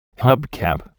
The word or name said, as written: hubcap